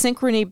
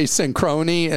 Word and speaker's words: Synchrony: I'm a longtime listener and big fan of the show, but when Clark mispronounced Synchrony Bank so badly, he caused me to spit Arizona iced tea all over the desk. – I always say Synchrony and it's Synchrony.